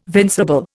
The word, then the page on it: vincible: Indian